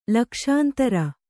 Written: ಲಕ್ಷಾಂತರ

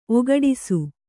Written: ಒಗಡಿಸು

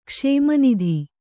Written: ಕ್ಷೇಮನಿಧಿ

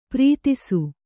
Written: ಪ್ರೀತಿಸು

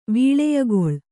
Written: ವೀಳೆಯಗೊಳ್

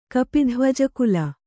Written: ಕಪಿಧ್ವಜಕುಲ

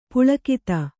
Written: ಪುಲಕಿತ

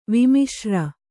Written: ವಿಮಿಶ್ರ

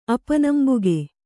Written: ಅಪನಂಬಿಕೆ

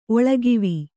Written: ಒಳಗಿವಿ

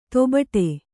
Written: ದಬ್ಬಾಡು

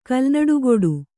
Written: ಕಲ್ನಾಡುಗೊಡು

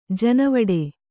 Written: ಜೇನುವುಟ್ಟಿ

